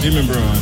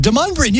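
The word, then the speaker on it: Demonbreun: Demon Brown.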